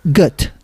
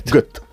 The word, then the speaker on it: Goethe: Y según he visto, se pronunciaría Goethe. – Goethe. – Mira, bueno, como pues, gota.